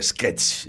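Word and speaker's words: esquech: S-Q-U-E-C-H. – E-C-H. – Vale, muy bien. No el sketch anglosajón. – No. Sketch. Hombre, quitarle esa T que tiene en inglés porque en realidad nosotros no la pronunciamos. Igual que le añadimos la E... que en inglés no tiene, porque empieza por ese líquida.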